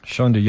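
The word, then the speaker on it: Shando: Shondo.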